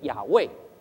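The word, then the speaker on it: 亚 卫 (יהוה): 耶是耶和华的缩写，耶和华的希伯来文读音是亚卫，耶稣的希腊文读音是亚素斯，所以耶是耶和华的缩写。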